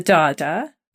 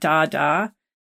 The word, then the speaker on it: Dagda: So our big guy, apart from Manon MacLear, is the Dada, the dog. It's spelled D-A-G-D-A for those of you listening at home, not Dada.